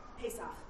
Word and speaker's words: Pesach: Pesach.